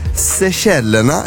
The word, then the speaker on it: Seychellerna: Om jag säger så här, Seychellerna, är det rätt uttal? – Mm. Ja, jättebra.